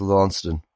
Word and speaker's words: Launceston: And then we got told by the two lovely old women outside that it's Launceston.